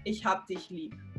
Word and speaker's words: ich hab dich lieb: Then you would say ich hab dich lieb. Ich hab dich lieb. Yeah, there you go. Hey, that was like perfect pronunciation.